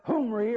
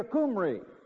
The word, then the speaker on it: Omri: In the King James, it's spelled O-M-R-I. It's pronounced with a harsh aspirant, Humri or Kumri.